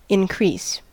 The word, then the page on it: increase: American English